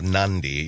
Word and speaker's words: Nadi: The main island on Fiji has a town called Nandi. Nandi. N-A-D-I. Or it's pronounced kind of Nandi, even though it's N-A-D-I.